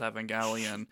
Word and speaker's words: Evangelion: Yeah, this is episode five of the anime called Neon Genesis Evangelion. I got made fun of for pronouncing it that way, but like I don't see an alternative. Like to pronounce it like evangelical just sounds dumb.